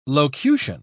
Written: locution

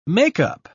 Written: makeup

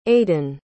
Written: aidan

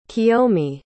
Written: Kiyomi

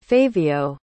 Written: favio

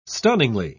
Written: stunningly

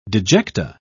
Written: dejecta

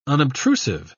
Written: unobtrusive

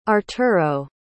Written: arturo

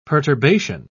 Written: perturbation

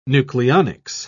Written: nucleonics

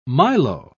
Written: milo

